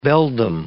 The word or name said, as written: beldame